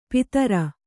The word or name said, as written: ಪಿತೃ